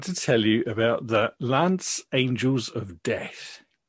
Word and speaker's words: Lainz: So it all happened in the Lance, it's spelled L-A-I-N-Z, and I've consulted Google Translate, and it says Lance.